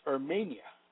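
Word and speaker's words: ermenia (ἑρμηνεία): The word for interpretation in the Greek is ermenia, pronounced ermenia, meaning to translate.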